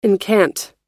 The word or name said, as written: incant